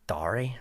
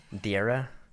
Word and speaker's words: Dara: It kind of works, man, because it's Irish spelling, so it gets butchered everywhere I go anyway. – Yeah. What have you been called on radio stations away from here or whatever? What's the worst you've heard? – Dari or Dur. Dara.